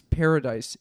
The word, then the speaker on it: Paradis: But two words, Ella, E-L-L-A, and Perdit, which is a French pronunciation, but really it's paradise in French. Oh. So it has an S at the end. P-A-R-A-D-I-S. And, Paradis.